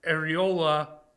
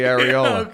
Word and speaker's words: Arreola: We got Adam Kownacki, Kownacki taking on Chris Arreola. I, I don't know if I'm saying that right. Yeah, yeah, you are. – It's okay. actually Arreola.